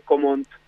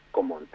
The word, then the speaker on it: Gaumont: Y sí, es Gaumont, la A y la U en francés se pronuncia como una O, como el cine Gaumont, que está ahí en Congreso, es Gaumont.